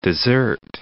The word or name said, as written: Mount Desert Island